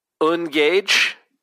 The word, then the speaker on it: UNGAJJE: Here's one from Ungage. I think that's how you say his Twitter name. I've never asked him, but it's Tim Stevens on Twitter. It's spelled UNGAJJE. – Sounds right.